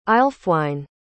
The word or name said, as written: aelfwine